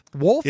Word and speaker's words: Wolfs: Wolfs? – It's W-O-L-F-S. Wolfs, not wolves.